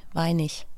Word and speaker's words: Weinig: Günther Werner Wolfgang Weining. – Ja, Weinig hedder han faktisk. – Weinig? – Ja, altså man staver det med G, men man udtaler det som ich, Weinig.